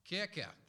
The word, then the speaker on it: Kirche: And where we get the English word church from comes from the German Kirche. My German very limited, so I probably pronounced it completely wrong. Kirche, there we go, thank you.